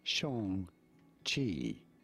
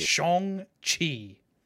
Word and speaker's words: Shang-Chi: How do you say it? Shang-Chi. – All right, yeah. Shang-Chi. – So I was wrong by saying Shang. – And I was wrong by saying Chi.